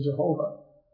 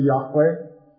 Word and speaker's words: Yahweh: little O-R-D. They took the vowel points for that and put it on the Tetragrammaton and got the pronunciation Jehovah. But archaeological study has demonstrated that it should be Yahweh as its proper pronunciation.